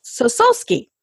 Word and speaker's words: Sosulski: And I believe I said your last name wrong. It's Sosulski. – Sosulski, correct.